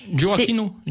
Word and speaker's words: Gioacchino: Un roman de Giacchino. Je ne sais pas si je le prononce bien. Je n'ai jamais su prononcer l'italien. Criaco. – Gioacchino, comme Joachim.